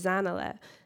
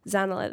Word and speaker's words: Zanella: And so I look back at Mrs. Niels, and I'm like, no, my name is Zanella. Zanella, that's how you pronounce my name.